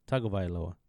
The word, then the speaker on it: Tagovailoa: Well, you put an N. There's no N. It's Tago. Tagovailoa.